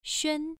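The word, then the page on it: xuan: tone 1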